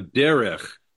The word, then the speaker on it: Derech: Before that one, 8:23, you have Derech. Derech Shlosh Yamin. – Right, so two syllables. Yeah. – Yeah, it's three right. Letters, two syllables, first syllable stressed.